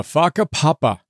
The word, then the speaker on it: whakapapa: First big idea, whakapapa, which is spelled W-H-A-K-A-P-A-P-A, whakapapa. First big idea, whakapapa. It's a Maori term that is spelled W-H-A-K-A-P-A-P-A, whakapapa.